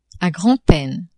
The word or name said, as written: a grand-peine